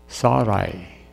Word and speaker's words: Sarai: God said to Abram, as for, and I'm going to pronounce this the way I think it should be pronounced, Sarai. Sarai. I at the end, not an H.